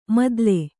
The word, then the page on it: ಮುತ್ತಲು: muttalu